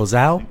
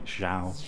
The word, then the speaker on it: Zhao: Is it Zhao or Zhao? – Zhao. – Zhao, sorry. I knew I pronounced it correctly.